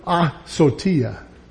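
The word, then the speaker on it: asotia (ἀσωτία): Well, you know, in verse 18, we see the word asotia. And I say it that way with a little emphasis on the ah, because it's very important.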